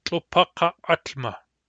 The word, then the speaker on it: tlopakaatma: If we put ut into this word, into tlopakakma, the result is tlopakaatma. Tlopakaatma. What is happening is the second Q of kak is to becoming a pharyngeal stop. And then the whole word is pronounced tlopakaatma.